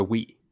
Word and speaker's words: Wii: The Wii? The Wii.